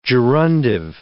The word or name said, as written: gerundive